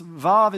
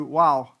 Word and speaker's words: Vav: And this section that we look at today is under the title of the sixth letter of the Hebrew alphabet, pronounced Vav in modern Hebrew and in ancient Hebrew, Wow, which is used over 50,000 times as a prefix, mostly as the word and or also.